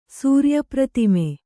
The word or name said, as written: ಸೂರ್ಯಪ್ರತಿಮೆ